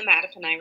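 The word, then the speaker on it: Mattaponi: Well, I grew up actually along the Mattapani River, which when I tell people in other places, I just tell people it rhymes with batted an eye.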